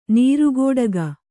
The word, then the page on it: ನೀರುಕುಟಿಗ: kannada; nīru kuṭiga